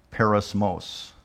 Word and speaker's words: peirasmos: and that word is it's pronounced perismos. And it's spelled P-E-I-R-A-S-M-O-S.